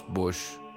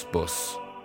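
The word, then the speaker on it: Bosch: آخ راستی نیاین بگین هیرونیموس بوش درسته، شما درست میگین، منم درست میگم. با تلفظ اهالی فلاند هیرونیموس بوس.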